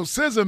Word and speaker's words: SZA: Yeah, so SZA, man, I think that's how you pronounce it.